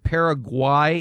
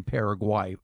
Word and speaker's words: Paraguay: I correctly pronounced it Paraguay. It's Paraguay, not Paraguay.